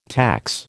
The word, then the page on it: tax: American English